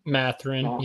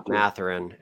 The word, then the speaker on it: Mathurin: Mathurin, yeah. – Mathurin.